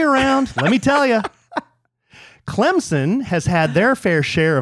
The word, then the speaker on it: Clemson: I don't know how you pronounce Clemson. – It's Clemson.